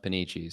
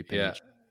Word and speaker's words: Panici: My last name is pronounced Panici. So my name is Midwestern rhyme for sure. Demetri Panici. – Yeah. Yeah, for sure. It's like panini except with a chi at the end.